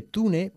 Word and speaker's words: tune: Se escribe tune, pero se pronuncia tune, okay? – Tune.